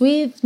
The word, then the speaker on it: we've: And since we've no place to go. We've, o som termina no V, porque esse E final não é pronunciado.